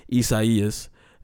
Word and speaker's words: Isaias: Hurricane Isaias,